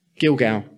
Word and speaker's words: Gilgal: Gilgal.